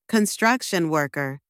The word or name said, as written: construction worker